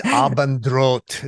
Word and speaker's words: Abendroth: It's Abendroth. It's like German for evening sun, red sun, but we say it wrong. So thank you so much, Roger, for continuing the heritage of saying it wrong.